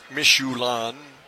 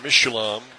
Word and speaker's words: Mishoulam: So Aaron Mischelum isn't spelled like the tire company, by the way. It's Mischulan. M-I-S-H-O-U-L-A-M. Mischulan.